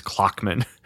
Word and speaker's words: Klochmann: Now, the clockmaker's name is Clockman, but it's spelled Klochmann, and it's wonderful.